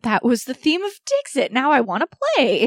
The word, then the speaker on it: Dixit: Dixit, or DC, if you're pronouncing it the French way. – DC? – But it's D-I-X-I-T, and it's a bit, it combines the ideas of like a Pictionary-ish style thing, but with apples to apples.